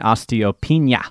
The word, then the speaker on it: osteopenia: I'm on the borderline between osteoporosis and osteopenia. Did I say that right? Osteopenia? – Yep.